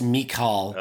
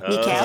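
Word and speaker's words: Michiel: No, it's not Michelle. It's Mikal. – Mikal. – It's Dutch. I thought he was French.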